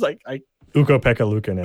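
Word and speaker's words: Ukko-Pekka Luukkonen: Ukko Pekka Luukkonen. – Ukko Pekka Luukkonen.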